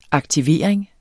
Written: aktivering

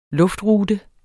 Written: luftrute

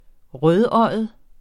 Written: rødøjet

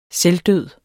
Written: selvdød

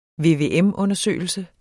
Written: VVM-undersøgelse

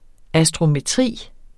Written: astrometri

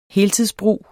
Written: heltidsbrug

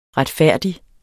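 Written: Retfærdig

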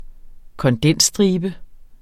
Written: kondensstribe

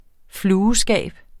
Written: flueskab

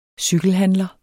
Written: cykelhandler

